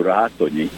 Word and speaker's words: Ratonyi: I always had to spell it out as R-E-I-C-H-M-A-N-N. Changing my name to Ratonyi, as we pronounce it in Hungarian, a well-known Hungarian name, I thought will result in never having to spell my name again.